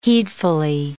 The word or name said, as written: heedfully